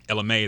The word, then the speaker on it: Ella Mai: Ella Mai, is that how you say it? – Yeah. – Oh, like Annie Mae? – Okay, yeah.